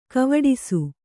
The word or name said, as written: ಕವಡಿಸು